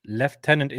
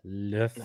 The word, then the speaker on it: lieutenant: Don Moran, lieutenant is just the wrong pronunciation. The word is a combination of lieu, as in lieu of, and tenant. Yeah, lieutenant.